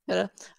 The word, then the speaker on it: Pitta: Pitta is correct. – Pitta.